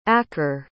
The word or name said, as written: aker